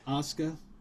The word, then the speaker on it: Asuka: Is that how you say her name? Asuka? – Yeah. – Asuka.